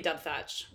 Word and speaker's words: Dubthach: D-U-B-H... I would say Dubthach. – Dubthach. – I would say silent H in the middle.